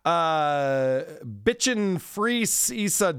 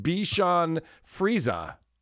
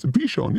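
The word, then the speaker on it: Bichon Frise: Bitchin' Freeza dog pronounced Bichon Frieza. – Yes, it's Bichon, yes.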